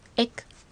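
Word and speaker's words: ek: 次に、エク。発音に注意してくださいね。 – 最後のクは小さいクの発音ですね。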